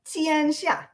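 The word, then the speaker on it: Tian Sha (天下): And I might say, can you tell us how to properly pronounce Tian Sha, a word I've used many times myself, mandate from heaven? – Tian Sha, right? Tian Sha, all under heaven.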